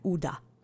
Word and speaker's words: uda: uda. Just saying. So let's say uda together three times. Again, it means it will succeed. Accent is on the first syllable.